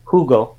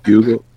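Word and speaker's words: jugo: J-U-G-O. I never remember how to pronounce that. – Hugo. – Hugo. – Hugo. – Hugo. – Yeah, it's a anyway. – The J is an H.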